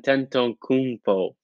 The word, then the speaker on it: Antetokounmpo: And Giannis Antetokounmpo. – It's not horrible for a split second. Giannis. He's Greek. Giannis, Giannis? – Antetokounmpo.